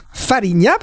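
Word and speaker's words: Fariña: Alors il faut dire Farinha parce qu'en Galice, à l'espagnol c'est même à la portugaise la prononciation pour être précis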